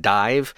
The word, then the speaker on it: DIIV: starting with the band Dive, spelled like Roman numerals, D-I-I-V.